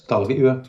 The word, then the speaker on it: Tallulah: Is it Tallulah? Is that how you say it? – Yes, Tallulah. Tallulah. Yes, Tallulah. Yeah.